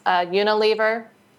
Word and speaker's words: Unilever: And is it pronounced Unilever? Is that correct? – Yeah.